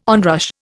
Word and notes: onrush: Indian

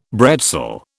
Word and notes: bretzel: British English

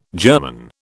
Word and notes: German: British English

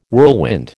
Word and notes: whirlwind: American English

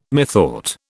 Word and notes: methought: British English

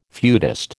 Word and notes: feudist: American English

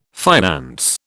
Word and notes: finance: British English

